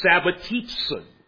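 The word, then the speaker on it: sabbatizō: And a Sabbath rest, again, may well have been a word coined from a related verb used in the Septuagint, a word pronounced sabbatizon. Now, what does that word mean? It does sound like sabbatismos, the noun, but what does sabbatizon mean?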